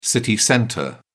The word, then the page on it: city centre: British English